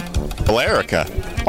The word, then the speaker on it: Billerica: How do you pronounce that? Billerica. There's an E in there. – Billerica. – Billerica, maybe? – Billerica. – Oh, that makes sense. Just leave out the E.